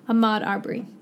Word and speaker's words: Ahmaud Arbery: But she, like, her name I heard most recently after Aubrey, say his name again. I'm sorry. – Ahmaud Arbery. – I said Aubrey and then I was like, that's not.